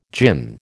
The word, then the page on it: djinn: American English